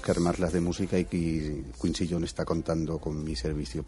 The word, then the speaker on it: Quincy Jones: Además estás inmerso ahora en un nuevo proyecto con Quincy Jones. Dime si me equivoco el nombre, porque es que yo para la pronunciación... – No, lo has dicho perfectamente.